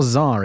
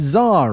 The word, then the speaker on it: ZAR: Z-A-R or ZAR? It's ZAR, right? It's all caps Z-A-R, but ZAR. And it was being invaded by the British Empire. Shocking. You see, the ZAR and its neighboring state,